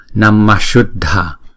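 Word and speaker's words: Namaḥ Śuddha: Thì khi đó đó chúng ta phải phát âm cái visarga này giống như chữ này luôn, giống như chữ S palatal này luôn, thì chúng ta đọc là Namashuddha, Namashuddha.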